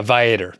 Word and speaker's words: Viator: And so I analyzed a couple of good sites and it looks like, I can't pronounce the word Viator, V-I-A-T-O-R.